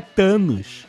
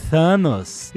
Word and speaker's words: Thanos: A gente falou da pronúncia de Thanos, que é com TH. Não é Thanos, é Thanos.